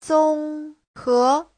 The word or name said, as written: zong1he2